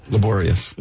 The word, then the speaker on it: laborious: Digging up, you know, your shrubs is a laborious task, you know, and... – Laborious. – Laborious. – You got the accent on the wrong syllable.